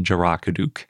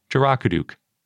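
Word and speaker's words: Dzharakuduk: I'm pretty sure it's pronounced Jarakuduk. It's spelled D-Z-H to start, but I think that makes it basically J sound.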